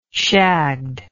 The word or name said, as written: shagged